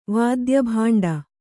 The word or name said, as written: ವಾದ್ಯಭಾಂಡ